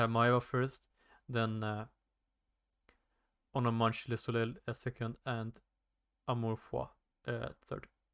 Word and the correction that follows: Amour Fou: You mean Amour Fou? – Amour-fou.